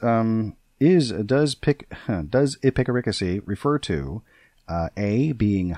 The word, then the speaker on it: epicaricacy: It is spelled E P I C. Uh, epic. A R I, and then C A C Y, and the stress is on the third syllable, so it's like a picaricacy.